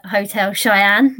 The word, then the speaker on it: Hotel Cheyenne: I might be saying it wrong, but Hotel Cheyenne, that's how I say it. – That's right. – Spot on. Yeah. Okay. – As far as I'm aware, that's spot on.